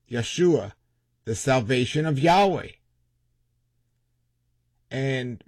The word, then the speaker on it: Yahweh: His name is Yahweh. Of course, some people will pronounce it different, but Yahweh.